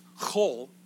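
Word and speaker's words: chol (חול): The Hebrew word kodesh, holy, the opposite of holy, the Hebrew term is chol. It's not like you're going to spit on somebody, chol. C-H-O-L, hard C-H-O-L.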